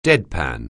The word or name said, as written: deadpan